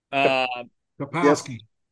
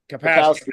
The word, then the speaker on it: Kapowski: Kelly, what's her last name? Capacity? – Kapowski. – Kapowski, thank you.